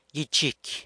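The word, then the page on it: gyichicy: San Lucas Quiaviní Zapotec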